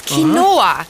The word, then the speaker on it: Quinoa: Also erstens ist es gar kein Getreide und zweitens heißt es Quinoa. – Quinoa.